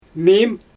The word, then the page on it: miim: Xyzyl